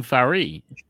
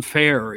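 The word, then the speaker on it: Ferré: Because he was the Giant Jean Farry. – Yes. I never pronounced that name right. I always called him John Fair, you know? – Yeah.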